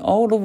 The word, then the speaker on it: Oulu: Okay, du wohnst in der größten Stadt in Nordfinnland, Oulu, habe ich das jetzt richtig ausgesprochen? – Halbwegs in Oulu wohnen wir, ja.